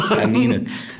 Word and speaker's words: Stanine: Wir haben es die ganze Zeit falsch ausgesprochen. Stanine.